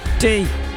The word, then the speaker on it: Teton: The Grand Teton. Teton. Well done. Is it a big squeezable pimple on your face? Says Adrian, playing for Radio Derby. A teton is a grand sofa bed you can sit on whilst waiting to tee off at golf.